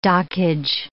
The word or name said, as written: dockage